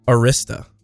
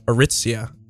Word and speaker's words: Arista: There's Arista, which I believe in the past I was calling Aritzia on accident.